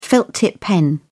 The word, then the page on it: felt tip: British English